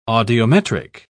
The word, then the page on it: audiometric: British English